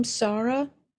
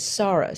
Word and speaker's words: Sarah: I ask people to pronounce my name Sarah, which includes the hay. – Yes, it does. – As far as how it's written in English, I don't feel energy. But, you know, even friends who know Hebrew will still say Sarah, and I will correct them. My name is Sarah. So it's definitely pronounced with the holy sound.